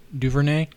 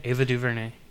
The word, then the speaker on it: DuVernay: And then Ava, I'm not saying her name right. – Duvernay. Ava Duvernay.